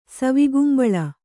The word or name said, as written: ಸವಿಗುಂಬಳ